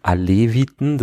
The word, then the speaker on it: Aleviten: unterscheidet häufig zwischen Aleviten, das ist das das türkische Wort und dem Alawiten in der arabischen Aussprache.